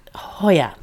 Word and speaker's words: Hoyer: Steny Moyers? Is that how you say his name? – I don't know. Steny, probably. – Steny. Okay. He got him on tape. – Hoyer.